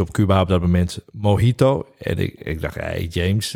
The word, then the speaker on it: Mojito: Ik dacht altijd dat het Mojito was. Ik heb ooit Spaanse les gehad. En dan leer je dat de J uitgesproken wordt als een G. Dus vandaar Mojito.